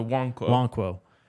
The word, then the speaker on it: Nwankwo: But Chidozie Nwankwo. I think you're right. I think the N is not pronounced actually. – Okay, so Nwankwo.